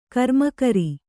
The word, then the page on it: ಕರ್ಮಕರ: karmakara